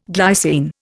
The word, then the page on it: glycine: Indian